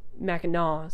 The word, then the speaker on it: Mackinac: So I have something on this mug that says Big Mac, even though the bridge is pronounced Mackinac, so it's really like the Big Ma.